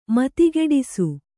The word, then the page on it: ಮತಿಗೆಡಿಸು: matigeḍisu